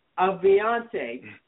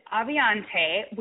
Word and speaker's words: Avionté: Avionte. Did I say that right? – Oh, yes. It's Avionte. We always have to pronounce it. Don't worry. Everybody always pronounces it incorrectly.